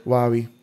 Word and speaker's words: Huawei: Huawei. It's spelled like Hawaii.